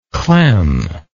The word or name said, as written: clem